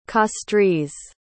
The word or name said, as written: castries